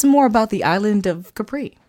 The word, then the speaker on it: Capri: He spoke Italian, and that's the way they pronounce it there. – Capri.